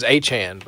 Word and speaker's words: Achane: I thought it was Achane, but... – I think it is. It's Don't Call Me Chain, and yeah. I blew it. It's Achane.